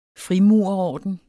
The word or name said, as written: frimurerorden